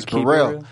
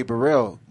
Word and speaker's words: Burrell: Like, I like to, my last name is Burrell, and it's spelled like Be Real.